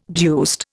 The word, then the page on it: deuced: Indian